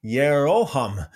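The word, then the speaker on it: Jeroham: Now there was a man from Ramathaim, Zophim, from the hill country of Ephraim, and his name was Elkanah, the son of Jehoham. I think I said that wrong. Yeroham, the son of Elihu, the son of Tohu, the son of Zuf, an Ephraimite.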